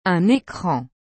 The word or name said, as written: un ecran